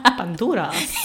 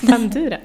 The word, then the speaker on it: Banduras: Och om vi då ska komma in på den sista teoretiska pusselbiten, så kommer den från Banduras sociala inlärningsteori. Hade jag konstigt uttal där. – Banduras. – Ja, det är lite spanskt. – Banduras. – Ja, Banduras. Sociala inlärningsteori.